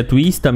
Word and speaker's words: Twista: Mas tem como pra Ence, que coloca o Twist como analista e abre vaga para novo coach. Não é Twist, é Twista mesmo. Eu que falei errado, mano. Né? Twista. Twista.